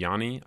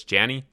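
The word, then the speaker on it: Jani: Emily Taylor, gameplay designer on Anthem, has tweeted us and said that it's actually pronounced Janny. Yarrow pronounces it like that, kind of like Johnny, but with an A. So it's Janny. It's not Yanny.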